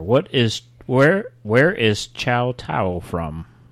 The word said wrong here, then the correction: Tsingtao: Yeah, it's spelled T-S-I-N-G-T-A-O. You've seen this. – Qingdao. – It's Qingdao. – It's pronounced Qingdao.